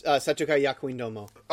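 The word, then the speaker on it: Seitokai Yakuindomo: I don't want to say it's like Shimaneta, but it reminds me of that other, like, Setokai Yakudomo. I think that's how you pronounce it. – Yes, Setokai Yakuindomo.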